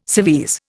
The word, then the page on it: civvies: Indian